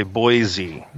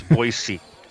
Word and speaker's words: Boise: We always know who's moved in here when they say Boise. It's Boise.